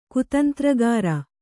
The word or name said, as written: ಕುತಂತ್ರಗಾರ